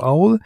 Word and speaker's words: OWL: Ähm, das ist die, also auf Englisch wird es dann ausgesprochen wie Eule, also Aul.